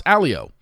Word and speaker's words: Allio: Michael, I've been calling him a Leo. I think everybody has since the beginning of time, since he showed up on Katie's season. Find out his last name is pronounced Alio.